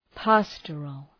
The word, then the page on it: pastoral: ‘pæstərəl